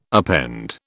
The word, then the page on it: append: British English